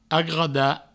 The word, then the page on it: agradar: agrada